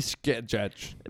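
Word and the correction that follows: Skjei: Brady Shea, actually, is how you say it. Brady Shea. – Wow, that's a Shea.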